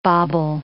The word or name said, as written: bobble